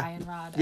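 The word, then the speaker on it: Eianrod: I don't know how to say this one. – Yeah. – Ianrod. – Yeah. – Probably. – Yeah, that's perfect. It's in Kyrian, so yeah. Hard word to say.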